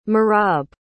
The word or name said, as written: merab